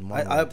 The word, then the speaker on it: Munro: I said Munro. I pronounce it Munro, like Marilyn Monroe.